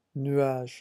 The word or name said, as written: nuage